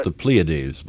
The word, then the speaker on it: Pleiades: I believe those are pronounced the Pleiades, by the way, but it's a picky point.